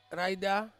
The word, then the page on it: raida: Ansus